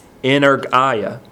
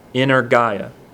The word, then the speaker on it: energeia: That word strong is the Greek word energia. Energia. It's spelled E-N-E-R-G-E-I-A. Looks like energia is what it looks like.